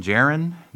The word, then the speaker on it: Djarin: Let's see, our main character Din Djarin, the D is silent, right?